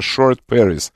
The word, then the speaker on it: Short Paris: Она, группа называется Short Paris, как short, короткий, и Paris, как Париж. Short Paris.